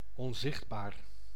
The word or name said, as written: invisible